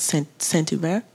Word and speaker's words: Saint-Hubert: You live in a suburb just outside of here called Saint-Hubert. Yeah. Did I say that right? – You said that.